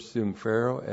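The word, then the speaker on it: sumphero (συμφέρω): Verb sumphero, S-U-M-P-H-E-R, long O. This is the dative plural of su, H-U-M-I-N, sumphero for you.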